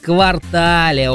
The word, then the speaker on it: квартал: Существует мнение, Олечка, что данное слово в значении «часть города» произносится с акцентом на последний слог, а в значении «четверть года» на первую гласную «а». Однако это не так. В словарях зафиксирован лишь один верный вариант произношения этого существительного. И он с ударением на последний слог. По-ентому правильно говорить «квартале», Олечка.